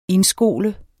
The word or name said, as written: indskole